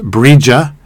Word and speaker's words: Brigit: This Celtic holiday is dedicated to the goddess Brigit, or pronounced Brija.